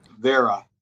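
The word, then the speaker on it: Wera: So Wira, if you're in Germany, it'd be called Vera, but, mm-hmm. Because W's are pronunciated as V's.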